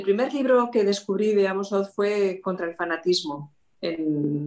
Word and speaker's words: Amos Oz: Sí, bueno, hay bastantes, pero yo destacaría a Amos Oz, no sé si se pronuncia así. Sí, sí.